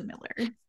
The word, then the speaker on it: Millar: It's it's my married name, but uh, my husband's family's French, and so I think it's probably like correctly pronounced Millar, but they've just Angli- anglinized it or whatever England whatever that word is. Um, yeah, so they pronounce it Miller.